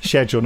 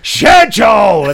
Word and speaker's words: schedule: Maybe you could have said, could I suggest you pronounce it schedule, not, you know, schedule!